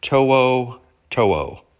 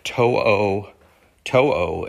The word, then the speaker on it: To'o To'o: That's really how you say his name if you want to say it along with me in case you really want to learn how to say it, To'o To'o. And then when you look at his name, how it's spelled correctly, it makes sense that you pronounce like that, just To'o To'o.